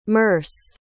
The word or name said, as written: merce